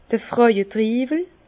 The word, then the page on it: d'r Frauetriiwel: Bas-Rhin; Herrlisheim